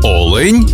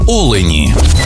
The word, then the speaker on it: олень: і в однині, і в множині наголос падає на перший склад – олень та олені.